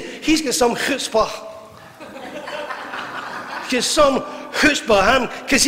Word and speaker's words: chutzpah: It's spelled chutzpah, but it's chutzpah.